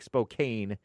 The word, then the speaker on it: Spokane: It looks like Spokane, but it's pronounced Spokane, I'm pretty sure. S-P-O-K-A-N-E, County, Washington State.